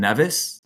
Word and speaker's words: Nevis: St. Kitts and Nevis. That's how you say it. And Nevis. St. Kitts and Nevis.